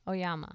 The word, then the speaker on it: Oyama: Oyama.